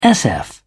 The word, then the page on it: sf: British English